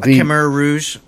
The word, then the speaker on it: Khmer Rouge: Khmer Rouge.